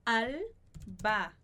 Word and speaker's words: albahaca: Tomate e albahaca, esse H não vai ser pronunciado, mas os dois As que vêm antes e depois, os dois são pronunciados.